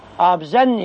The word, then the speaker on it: آبزن: والصواب أنها لا تنطق هكذا ولا تكتب هكذا، ولكن لأنهم يعربون فإذا عربوا فلا تسأل كيف يكتبون ولا كيف ينطقون. طبعا البخاري رحمه الله كان متقنا للفارسية، كان متقنا لعدة لغات، ولكن هذا من النساخ، هي أصلها آب، آب بمعنى الماء في اللغة الفارسية، آب معناها الماء، آبزن يعني مخزن الماء أو مستودع الماء ومكان الماء، فتنطق بالمد.